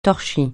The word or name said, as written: torchis